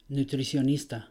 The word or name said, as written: nutricionista